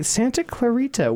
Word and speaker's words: Santa Clarita: Santa Clarita.